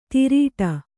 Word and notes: ತಿರೀಟ: Kannada

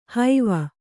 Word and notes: ಹೈವ: haiva

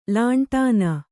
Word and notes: ಲಂಟಾಣ: kannada

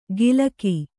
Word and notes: ಗಿಲಕಿ: gilaki

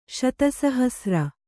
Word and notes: ಶತಸಹಸ್ರ: śata sahasra

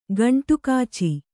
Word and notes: ಗಂಟುಕಾಚಿ: gaṇṭu kāci

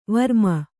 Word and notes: ವರ್ಮ: varma

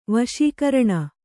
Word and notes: ವಶೀಕಾರಿಣಿ: vaśikāriṇi

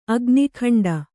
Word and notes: ಅಗ್ನಿಖಂಡ: agnikhaṇḍa

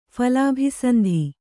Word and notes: ಫಲಾಭಿಸಂಧಿ: Kannada

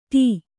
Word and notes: ಠೂ: Kannada

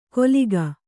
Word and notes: ಕೊಲಿಗ: koliga

